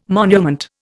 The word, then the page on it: monument: Indian